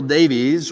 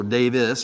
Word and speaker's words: Davies: Samuel Davies, or Davis, but it's spelled Davies, was a Presbyterian minister, probably one of the most important of the colonial period in American life.